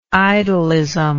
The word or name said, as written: idolism